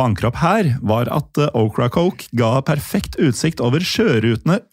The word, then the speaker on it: Ocracoke: Hvordan ville du uttalt den øya, Jim? – Ocracoke? – Ja, det var ikke så verst.